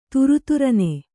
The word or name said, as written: ಥರಥರನೆ